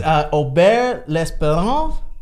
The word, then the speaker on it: Aubert L'Espérance: It's Aubert L'Espérance.